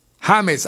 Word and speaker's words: James: Y el último, el décimo, James, así como lo estoy pronunciando, James Rodríguez, es colombiano, 78 millones de dólares.